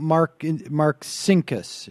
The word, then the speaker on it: Marcinkus: Talk to us, too, about Paul Marcinkus, if I'm pronouncing that correctly.